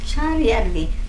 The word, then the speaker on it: Saarijärvi: Oh, Saarijärvi. – How do you say it? – It's marked here. – On the postcard here? – Saarijärvi.